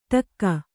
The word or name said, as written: ಟಕ್ಕ